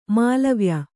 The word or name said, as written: ಮೇಲೆವಾಯ್